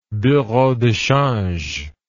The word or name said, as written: bureau de change